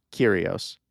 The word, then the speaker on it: Kyrgios: I mean, the Greek pronunciation of it would be Kyrgios. – Oh, here we go. – So you would just say like Kyrgios is fine. Do not say the G. Do not say Kyrgios or whatever.